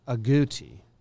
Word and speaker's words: agouti: How do you spell it? – A-G-O-U-T-I. – Agouti.